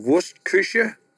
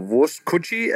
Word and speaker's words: Wurstküche: and to Wurstküche. Or Wurstküche, as I think most Americans think it's pronounced, where they have a sausage concept, which I think is phenomenal, and that is predator and prey.